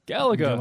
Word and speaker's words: Galaga: How did you pronounce it? Galaga? Galaga? – Galaga.